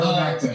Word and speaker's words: ivermectin: Or invectimen. Is that how you say it? – Ivermectin.